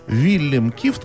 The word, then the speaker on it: Вильгельм Кифт: На самом деле, Вильгельм Кифт, как правильно его имя произносится по-голландски, его должность называлась генерал-директор.